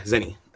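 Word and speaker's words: Zeni: Is that how you say it? So, Zenny? – Yeah, Zenny. ZeniMax Media, which is was the parent company of Bethesda.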